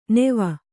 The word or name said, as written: ನೆವ